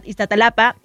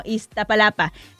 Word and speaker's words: Iztapalapa: Un saludito hasta Iztapalapa. Ay, pero ya viste que yo lo dije mal. Ah, no, Iztapalapa. Se cancela, ya lo dije bien.